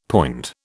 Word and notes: poind: British English

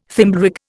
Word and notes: Thimblerig: Indian

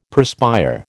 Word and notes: perspire: American English

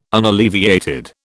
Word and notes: unalleviated: British English

